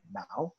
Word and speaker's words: Mouw: And my last name is spelled M as in Mary O U W, Brandon Mouw.